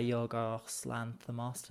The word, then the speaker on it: Yorgos Lanthimos: Emma Stone, Willem Dafoe, Mark Ruffalo, all directed by Yorgos Lanthimos.